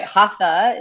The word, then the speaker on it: Hatha: No, you're saying it right. Hatha is what we typically call it.